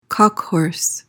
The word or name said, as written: cock-horse